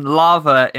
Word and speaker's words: laver: I mean, for one, lava in this term is spelt L-A-V-E-R.